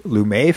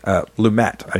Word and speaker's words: Lumet: There's a lot of stage actors in the original film as well, the Sidney Lumet film. Lumet, I should say. I'm mispronouncing it.